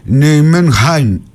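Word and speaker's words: Nijmegen: Εγώ να ημέγεν τη λέμε την ομάδα. Η Ολλανδική όμως, λέει, προφορά είναι Νιουμενχάιν, είναι κάπως βαθύ το χ, δεν μπορώ να το πετύχω.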